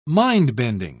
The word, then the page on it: mind-bending: British English